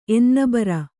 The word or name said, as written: ಎನ್ನೆಬರ